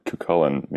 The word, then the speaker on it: Cuchulain: Cuchulain, or how it's more often pronounced over yonder in Ireland, Cuchulain means the hound of Cullen, which is like this like ancient province, basically where Belfast, Northern Ireland is.